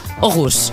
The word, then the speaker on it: Aarhus: L'anglès, bueno, és que l'anglès va haver un moment que em vaig enxitxar molt perquè vaig anar a Aarhus, que en realitat es diu Aarhus. – Aarhus, ja. – Es pronuncia així.